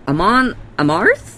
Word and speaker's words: Amon Amarth: Amon Amarth.